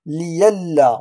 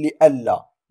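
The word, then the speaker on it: لئلا: هنا كذلك يتم إبدال الهمزة بهذه النقطة السوداء هنا مع بقاء حركتها وننطقها هنا ياء، نقول لأنها سبقتها كسرة نقول ليلا ولا نقول لألا، ليلا هذا فيما يخص الإبدال.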